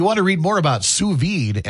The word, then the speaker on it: sous vide: No, listen, I said sous vide. If you're French and know what that means, you know I nailed that pronunciation because I Googled it.